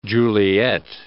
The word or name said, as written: Juliett